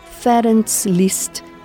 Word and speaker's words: Ferenc Liszt: Ferenc Liszt é a pronúncia húngara de seu nome.